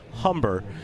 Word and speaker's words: Humber: had the silent H. But no, it is Humber, Ramon.